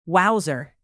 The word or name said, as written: wowser